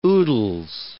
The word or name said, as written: oodles